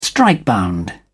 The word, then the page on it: strikebound: British English